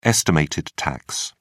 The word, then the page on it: estimated tax: British English